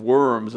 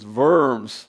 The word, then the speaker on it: Worms: At the Diet of Worms, sometimes we pronounce it the Diet of Worms, it's Worms, this discussion that actually turned into a trial.